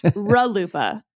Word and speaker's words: RLUIPA: I pronounce this RLUIPA. How do you pronounce it? – RLUIPA. – RLUIPA. – RLUIPA. – Okay, that's definitely incorrect, listeners. So I just want you to know if you're dropping it in casual conversation, it's pronounced RLUIPA.